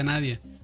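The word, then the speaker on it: Nadiya: Que no sé qué es como Nadia algo escribe Nadilla, pero se pronuncia Nadia.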